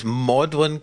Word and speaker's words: Magdalen: Can I just confirm something? You said that this is Magdalen College. Is that the one that's spelled Magdalen? – That's the one.